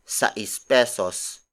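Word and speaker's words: seis pesos: While 6 pesos also means sais pesos. Sais pesos. Sais pesos. Sais pesos is spelled as S-A-I-S space P-E-S-O-S.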